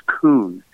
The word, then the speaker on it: Kuhn: There's two different ways you can pronounce it. My name is spelled K-U-H-N, and the Jewish pronunciation is Kuhn, like, you know, Thomas Kuhn and some of the other, you know, famous authors and stuff. The German pronunciation is Kuhn, K-U-H-N, with the umlaut on top of the U.